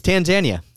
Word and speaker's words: Tanzania: No, it's Tanzania.